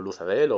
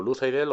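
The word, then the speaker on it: Luthadel: El reino de este, del Lord Legislador, se llama Imperio Final, con la capital, em, con su capital Lutadel, o Luzadel, o Luzaidel, o como lo queráis pronunciar, yo lo pronuncio Lutadel.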